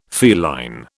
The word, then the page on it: feline: British English